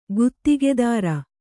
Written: ಗುತ್ತಿಗೆದಾರ

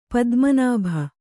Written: ಪದ್ಮನಾಭ

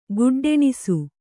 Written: ಗುಡ್ಡೆಣಿಸು

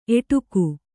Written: ಏಡೂಕ

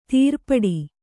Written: ತಿರುಪಾಟ್ಟು

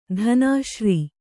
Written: ಧನಾಶ್ರೀ

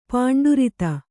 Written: ಪಾಂಡುರಿತ

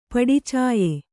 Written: ಪಡಿಚಾಯೆ

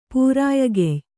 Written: ಪೂರಾಯಗೆಯ್

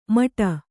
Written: ಮಟ

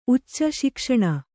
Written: ಊಚಿ

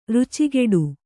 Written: ರುಚಿಕಟ್ಟು

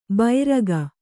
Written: ಬೈರಗ

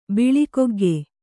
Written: ಬಿಳಿಕೊಗ್ಗೆ

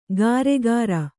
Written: ಗಾರೆಕಾರ

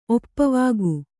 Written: ಒಪ್ಪವಾಗು

